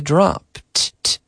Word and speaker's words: dropped: Yesterday I dropped. Dropped. So the T sound, I dropped.